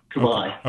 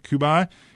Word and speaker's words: Kubaj: And you'll have to help me with the pronunciation, Lorelei Kubaj or something like that. – Lorelei Kubaj. Kubaj. – Okay, Kubaj.